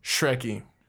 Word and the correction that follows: Shkreli: It's Shkreli, isn't it? – Shkreli. – Shkreli. – Shkreli? Is that how you say it? – Yeah.